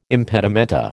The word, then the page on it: impedimenta: American English